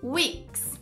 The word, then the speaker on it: weeks: Semanas, weeks. Você faz um U no início, weeks.